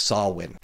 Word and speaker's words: Samhain: With this in mind, Samhain is how it's spelt. It is pronounced Samhain.